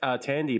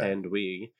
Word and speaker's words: Thandiwe: Yeah, because it's still pronounced Tandy. It's not Thandiwe.